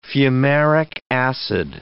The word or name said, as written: fumaric acid